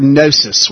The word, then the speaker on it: gnosis: And then for knowledge, he uses the word gnosis, which has a silent G, G-N-O-S-I-S.